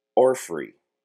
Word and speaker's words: orphrey: The word of the day is orphrey. Or at least that's how I'm told that it needs to be said. – You're pronouncing it correctly.